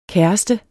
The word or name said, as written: kæreste